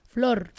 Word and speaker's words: Flor: Thank you for saying my name like that. – What? Yeah. – With the rrr. – Flor. – I grew up people being like Flor, like the floor.